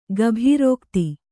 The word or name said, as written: ಗಭೀರೋಕ್ತಿ